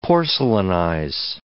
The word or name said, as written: porcelainize